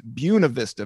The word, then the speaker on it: Buena Vista: And you came, and what we're talking about is a small town called Buena Vista, Virginia, but it's pronounced Buena Vista, Virginia.